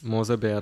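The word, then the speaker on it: Moser Baer: Mose Bar was the company that used to make CDs. – Mose Bear, bro. – That's how it's pronounced? – Yeah.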